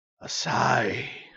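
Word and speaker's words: Asahi: It's usually the classic Sapporo, but this is called Asai, is the proper Japanese pronunciation.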